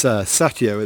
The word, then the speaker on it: Satio: It's Satio, at least that's how it was being pronounced at the press conference.